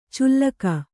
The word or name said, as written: ಚುಲ್ಲಕ